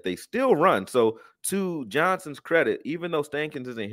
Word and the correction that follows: Steichen: here anymore, if that's how you say his name, you know, Steichen, yep.